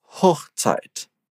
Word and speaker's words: Hochzeit: Hochzeit.